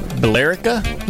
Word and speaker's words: Billerica: There's an E in there. – Billerica. – Billerica, maybe? – Billerica. – Oh, that makes sense. Just leave out the E.